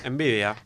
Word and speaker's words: Nvidia: Por aquí tenemos Square Enix y Nvidia, ¿lo he dicho bien? – Nvidia.